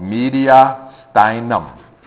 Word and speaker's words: mediastinum: I think most people mispronounce it, so I'm going to say it. It's called mediastinum. Mediastinum. Go ahead and say it like that. Mediastinum. Yeah. You might hear mediastinum and all kinds of really cool things, but mediastinum is the way we say that.